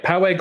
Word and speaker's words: Poway: Am I saying that right? – Poway Gardens.